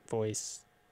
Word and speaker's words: Vhoysee: It's called Eat from the release Voice, which is spelled V-H-O-Y-S-E-E, Voice, right?